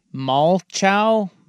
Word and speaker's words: Malchow: They're saying it's pronounced like the replacements quarterback Shane Falco, so Malcho.